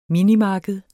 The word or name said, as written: minimarked